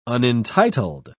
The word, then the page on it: unentitled: British English